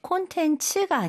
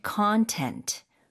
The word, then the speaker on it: contents: 발음 주의하셔야 돼요. 콘텐츠가 아니고 콘텐츠. 콘텐츠. – 아, 앞쪽에 강세가 많이 가네요.